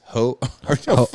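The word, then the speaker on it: Heaux Tales: The name of her new album is, I'm going to butcher this, Hoxtails, H-E-A-U-X, Hotails.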